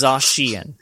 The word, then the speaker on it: Zacian: Like Zacian, which I've been pronouncing wrong. I've been pronouncing it Zacian. Very slight difference, isn't it Zacian? – No, it's Zacian. – It's like, I know. It's not Zacian, like it's supposed to be.